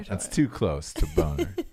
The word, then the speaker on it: Bonar: That is B-O-N-A-R. – It's probably Bonner, not Boner.